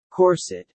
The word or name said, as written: corset